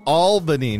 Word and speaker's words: Albany: It is Albany, Nate. – Albany. Albany. Albany.